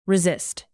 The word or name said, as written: resist